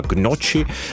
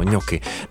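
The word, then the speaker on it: gnocchi: Spousta z nás by ho možná vyslovilo jako třeba gnoči, ale jako všichni víme, že správně jsou to ňoky.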